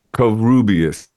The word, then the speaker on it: Covarrubias: Covarrubias, yeah.